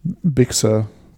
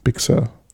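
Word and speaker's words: Big Sur: Big Sir. Big Sir. – Und beides equally betont.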